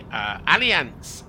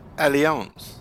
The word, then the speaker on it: Allianz: Sports Business wrote that the Allianz and Sporting. – I believe it's pronounced Allianz. – Allianz.